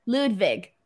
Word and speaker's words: Ludwig: Ludwig. – Yeah, but it's with a W, so it throws me up, and I want to say Wig instead of Vig.